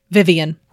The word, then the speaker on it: Vyvyan: Vivian is spelled V-Y-V-Y-A-N, which apparently is a Welsh name, Vivian.